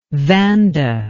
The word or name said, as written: vanda